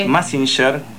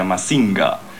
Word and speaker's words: Mazinger: Mazinger, que en realidad en japonés creo se pronuncia Mazinga, y significa dios o demonio, depende quién lo pilotee.